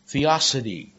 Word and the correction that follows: theodicy: T-H-E-O-D-I-C-Y. So it's T-H-E-O, it's Theodicy, D-I-C-Y.